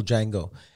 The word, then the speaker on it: Django: But that was the actual Django. And he says, the D is silent.